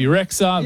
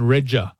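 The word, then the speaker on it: Rexha: Pronounce it BB Rexa, which is what I think she does in her American world as well. But in Albania, her actual name is Bebe, which means B, and Regja. Regja.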